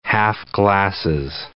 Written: half-glasses

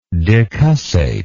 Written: decussately

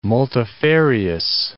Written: multifarious

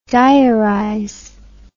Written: diarize

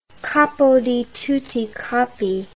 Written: capo di tutti capi

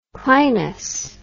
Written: clinous

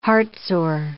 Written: heartsore

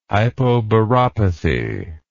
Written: hypobaropathy

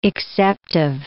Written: exceptive

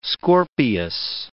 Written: Scorpius

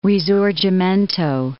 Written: risorgimento

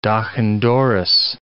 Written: doch-an-dorris

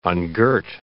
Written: ungirt

